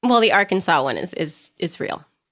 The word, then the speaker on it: Arkansas: Arkansas law regulates the pronunciation of the state's name, stating that the only true pronunciation is that received by the French from the Native Americans and is pronounced in three syllables with the final S silent, the A in each syllable with the quote-unquote Italian sound, and the accent on the first and last syllables.